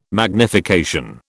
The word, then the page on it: magnification: British English